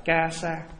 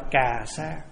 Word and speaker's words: ca sa: Chữ y á là tiếng Ấn, tiếng của mình, mà nói đúng tiếng Phạn là ca sa, hay đọc là cà sa.